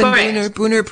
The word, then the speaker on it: Buhner: And so if you want to look him up, his last name is spelled B-U-H-N-E-R, Stephen with a P-H, Stephen Booner Protocol, Stephen Booner COVID Protocol.